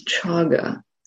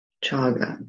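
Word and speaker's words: cāga: The Pali word is chaga. That's spelled C long A G A, chaga. And it has two important and related dimensions. So chaga is sometimes translated as generosity, and that's the first dimension.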